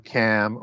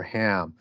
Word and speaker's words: Cham: Cham or Ham, C-H-A-M, depending on how you want to pronounce that as Cham, Cam, or Ham.